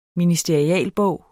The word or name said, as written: ministerialbog